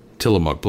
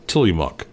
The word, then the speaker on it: Tillamook: She says try saying "till you muck" quickly for Tillamook, like Till Tillamook.